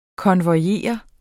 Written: konvojere

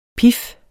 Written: pif

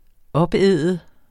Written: opæde

